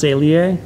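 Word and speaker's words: Saleae: So it's pronounced Salier. – Salier. I've been saying it wrong all this time.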